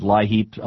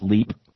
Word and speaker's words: LIHEAP: Some people pronounce it LIHEAP, others call it LEAP.